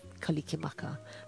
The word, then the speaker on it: kalikimaka: Every Hawaiian word ends with a vowel sound. So it was, and you can't have two consonants together, so you've got to put vowel sounds in between them. So that's how we come up with kalikimaka.